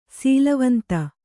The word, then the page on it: ಸೀಲವಂತ: kannada